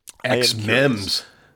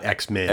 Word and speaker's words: XMEMS: XMEMS, not X-Men.